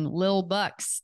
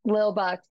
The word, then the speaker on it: Lil Bucks: I'm excited to hear your story in building Lil Bucks. Did I say it right? – Yes, that's right. Lil Bucks. – It's like little, but rolling, like Lil, L-I-L.